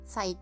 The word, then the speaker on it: PSY: PSY 多分サイサイって読みますあのあれです昔有名になったカンナムスタイルあの歌ってるおっちゃんです。